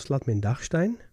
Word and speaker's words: Schladming-Dachstein: En ja, we hebben natuurlijk ook nog de regio rond Schladming-Dachstein. Zeg ik het goed? – Ja. – Dan hebben we nog de regio Schladming-Dachstein.